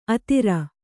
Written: ಅಧೀರ

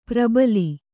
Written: ಪ್ರಬಲಿ

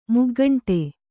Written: ಮುಗ್ಗಂಟೆ

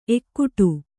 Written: ಎಕ್ಕಡಿ

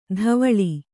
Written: ಧಾವಳಿ

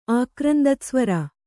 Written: ಆಕ್ರಂದತ್ಸ್ವರ

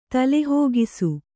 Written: ತಲೆಹೋಗಿಸು

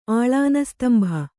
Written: ಆಲಾನಸ್ತಂಭ

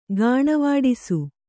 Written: ಗಾಣವಾಡಿಸು

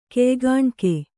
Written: ಕೆಯ್ಗಾಣ್ಕೆ

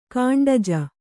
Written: ಕಾಂಡಜ

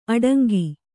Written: ಅಡಂಕೆ

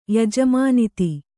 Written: ಯಜಮಾನತಿ

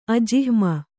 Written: ಅಜಿಹ್ಮ

